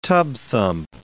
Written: tub-thump

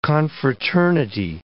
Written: confraternity

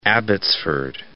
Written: Abbotsford

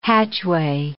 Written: hatchway

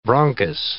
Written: bronchus

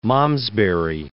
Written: William of Malmesbury

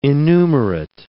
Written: innumerate

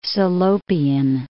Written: Salopian

